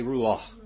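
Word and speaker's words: Ruach (רוּחַ): Ruach, say Ruach.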